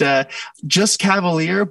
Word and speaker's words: Cavaliere: It sounds better when you say it that way because you do give it a little bit of the flair. But just Cavaliere, but I won't even try to, you nailed it is what I'm going with yours.